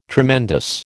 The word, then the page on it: tremendous: American English